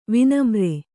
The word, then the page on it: ವಿನಮ್ರೆ: Kannada